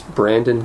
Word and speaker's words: Brandon: Yeah, it's Brandon Gale Watson.